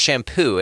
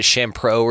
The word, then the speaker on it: Champoux: No, he's amazing, like a sweetheart of a guy, but it's pronounced shampoo, and I'm just like, okay, you know what I mean?